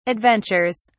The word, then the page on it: adventures: British English